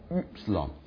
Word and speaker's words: Upsilon: 那这个发音上那个德文的 U 是一样的 Upsilon。